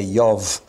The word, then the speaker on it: Job: Well, now we come to the book of Job, or should I say Yov, because in Hebrew J is pronounced like a Y and B like a V. But we'll be kind to you because we're English and we'll talk about Job.